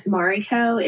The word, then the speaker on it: Mariko: only because Mariko is sometimes tricky for people to pronounce. It's Japanese.